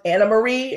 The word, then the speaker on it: Anne Marie: We find out, first of all, that Anne Marie is really pronounced Anna Marie. It's Dutch. – Yes, it's Dutch. So that is how we are supposed to pronounce it, you guys. It's Anna Marie, but I actually don't hate her.